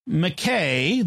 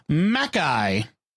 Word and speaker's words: Mackay: I want to just make one small critique on the surname McKay that you pronounced as it appears, but it actually pronounced Mackay.